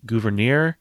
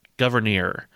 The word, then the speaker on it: Gouverneur: And Abigail Adams, who apparently, you know, was an expert, says, yeah, his first name is pronounced Gouverneur, but there's this city that he, or maybe like a little town, that he helped found, and they pronounce the city's name Gouverneur.